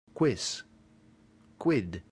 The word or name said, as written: quis